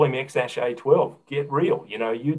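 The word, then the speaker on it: X Æ A-12: And of course, his name is XASH-A12. That's how it's pronounced.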